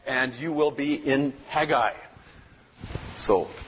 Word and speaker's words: Haggai: Not Haggai or whatever, but Haggai is the way it's pronounced in Hebrew.